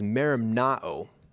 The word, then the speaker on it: merimnao: The pronunciation of it is merimnao, and it has a couple of subtly different meanings.